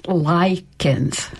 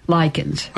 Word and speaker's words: Likens: My maiden name was Likens. – Likens? – Likens. – Okay. – Rita. – See, it's spelled I-L-I-N-K-S here on my piece of paper.